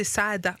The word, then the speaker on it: Saada: Et, j'aime bien parce que tu sais, moi j'ai toujours dit Warren Sada, mais en fait c'est Saada. – Saada, 2A. – C'est vrai qu'il y a les 2A, il faut et il faut les prononcer.